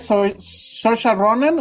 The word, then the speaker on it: Saoirse Ronan: Número 10, Saoirse Ronan, ¿o cómo se dice? ¿Sí lo dije bien? – Ah, sí, sí, total. No